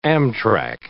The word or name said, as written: Amtrack